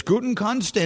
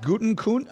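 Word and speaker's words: Gutekunst: There's Gutenkunst, and, and, and uh, which is how I'm told now to say his name, right? I've been saying it wrong all this time. Brian Guttenkunst. It's not Guttenkunst.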